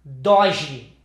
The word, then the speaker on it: Dodge: Dodge is in English is like dodge, like to duck. – Ah, right. – Like dodgeball.